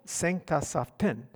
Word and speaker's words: Sankthansaften: You have to emphasize the last syllable. Sankthansaften.